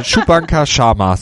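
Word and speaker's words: Shubhankar Sharma: Chubanka Sharma. – Ich hätte jetzt am Anfang gesagt Chewbacca, aber das war was anderes. Das war jemand anders. Chubanka Sharma, so heißt er, keine Witze mit Namen, also auch nicht böse gemeint, lieber Herr Sharma.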